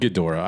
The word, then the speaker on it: Ghidorah: Ghidorah.